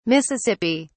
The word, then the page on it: mississippi: British English